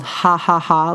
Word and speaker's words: Jajaja: And one of my favorite restaurants now is called Ha Ha Ha, like J-A-J-A-J-A. For those that don't know, in Spanish, H-A is pronounced J-A.